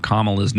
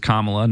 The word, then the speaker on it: Kamala: Kamala's name. I guess I've been saying it wrong. Apparently it's Kamala, not Kamala.